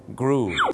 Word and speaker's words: grew: มันจะต้องออกเสียงสระอูครับสระอูถูกต้องครับอย่างเช่น grow ก็ต้องกลายเป็น grew